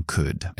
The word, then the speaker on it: could: y esa L que llevan esas palabras eh, no podéis resistir en pronunciarlo, sin embargo no se oye, es una letra muda totalmente. Entonces, vamos a, empezar con, con could. Es fonéticamente es una K, una especie de U y la D ahí al final.